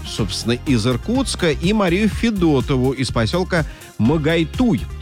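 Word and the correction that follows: Могойтуй: Ударение на второй слог. Магойтуй. Вот, извините, вот так вот. Магойтуй.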